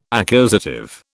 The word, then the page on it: accusative: British English